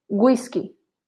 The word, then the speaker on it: güisqui: And if we if we add two dots above the U, it's not silent anymore. Guero, Güisqui.